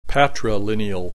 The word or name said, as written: patrilineal